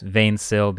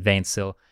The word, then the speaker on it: 20Syl: which is also known as Vainsill, because 20SYL is the spelling of it, but it's not English, so you pronounce it Vainsill.